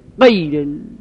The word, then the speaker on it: غیر: مثلا غیر المغضوب میگن غیر، با قاف تلفظ میکنن، غلطه.